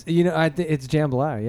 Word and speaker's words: jambalaya: Well, it's jambalaya, yeah. – It's not jambalaya? – It's not jambalaya. It's like absolutely spelled like J-A-M-A.